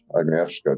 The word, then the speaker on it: Agnieszka: Thank you so much, Agnieszka.